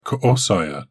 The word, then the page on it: ko-saya: Galindri